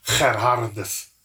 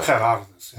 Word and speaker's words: Gerhardus: My second name is Gerhardus. – Gerhardus. Very good. Is this like in Afrikaans or is this in... – That's just the way it's pronounced in Afrikaans. – Yeah. Okay, well. – Gerhardus, yeah.